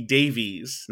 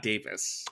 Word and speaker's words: Davies: It's pronounced Russell T. Davies, not Davis. – Okay. – Like Davis.